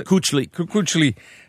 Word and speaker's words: Kuechly: Kuechly.